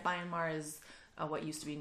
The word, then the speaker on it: Myanmar: Am I saying that right? – Myanmar? – Myanmar, yeah. Had their very first Pride.